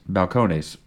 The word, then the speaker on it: Balcones: Balcones, how is it pronounced? – Balcones? – Balcones.